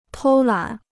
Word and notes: 偷懒: tōu lǎn

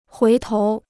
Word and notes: 回头: huí tóu

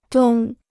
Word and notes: 东: dōng

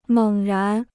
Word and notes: 猛然: měng rán

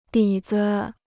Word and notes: 底子: dǐ zi

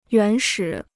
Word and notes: 原始: yuán shǐ

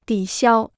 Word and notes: 抵消: dǐ xiāo

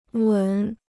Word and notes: 吻: wěn

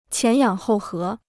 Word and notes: 前仰后合: qián yǎng hòu hé

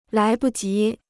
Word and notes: 来不及: lái bu jí

